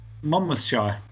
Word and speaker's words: Monmouthshire: How do you pronounce it? Monmouthshire? – Monmouthshire. You can always trust my pronunciation.